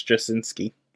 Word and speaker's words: Straczynski: The C is silent. I think it's Straczynski.